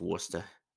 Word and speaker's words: Worcester: I said it right. It's Worcester. They were talking about that on the pesky podcast.